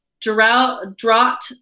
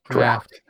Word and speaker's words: Draught: It says Guinness Draught Stout. – Draft. – And it says, enjoy chilled straight from the bottle. Did I say that wrong? Is there something? What did I do? – Draft. – No, there's a drought of Guinness because she's done with it. It's a drought. – Yeah, I know. That's how that's pronounced draft.